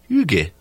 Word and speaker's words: hygge: I mean, they're clean and yet – they're both clean and embrace hygge as a sense.